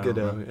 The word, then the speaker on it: Gadot: I mean, and Gal Gadot, is that how you say her name, or is it Gadot? – Gadot, yeah.